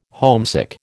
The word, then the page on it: homesick: American English